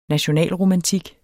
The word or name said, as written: nationalromantik